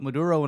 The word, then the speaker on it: Maduro: Yeah, and I thought that because there were so many pictures of Chavez and Maduro, am I saying it right? – Mm-hmm. – Better than DJ? Mm-hmm. But there were a lot of, they were just trying to put Maduro in a lot of places where he would just kind of piggyback onto the image of Chavez.